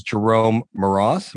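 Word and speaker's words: Jerome Moross: So let's look at someone who actually did have a Broadway succès d'estime, and it was hardly a hit, but this is Jerome Maras. Am I saying that correctly? – You are indeed.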